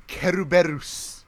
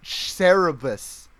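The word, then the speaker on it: Cerberus: Look, Jay, I don't want to hear mispronunciation of words after we got Keruberus. Alright? That was some bullshit. That was actual bullshit. You cannot tell me that Japanese people can't pronounce the Cerebus.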